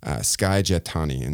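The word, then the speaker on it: Skye Jethani: So it's Sky is S-K-Y-E and Jatani is J-E-T-H-A-N-I, Sky Jatani.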